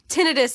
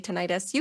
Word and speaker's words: tinnitus: So tinnitus seems a little more appropriate. But like, I think in the UK, they all say tinnitus. You can say it. I don't care how you say it. – Okay. – They're both accepted.